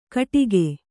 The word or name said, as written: ಕಟಕ